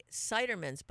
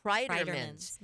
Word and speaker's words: Kreiderman's: I have to say it like I always say Cidermans, but it's Kreiderman's. – Mm-hmm. – Like cry.